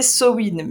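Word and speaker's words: Samhain: J'ai entendu que c'était Sowin, mais bon, on sait que techniquement, les gens ne vont pas le prononcer comme ça parce qu'on n'est pas irlandais.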